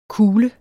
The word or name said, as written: kule